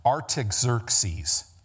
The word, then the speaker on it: Artaxerxes: Um, I'm sorry, I'm saying Cyrus let me say his name right Artaxerxes, the king of Persia, on March 14th commanded Jerusalem to be built.